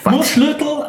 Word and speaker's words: Murschlötel: Das heißt nämlich ganz genauso. Murschlötel haben sie auf ihrer Homepage, weil so viele Leute fragen, wie es ausgesprochen wird.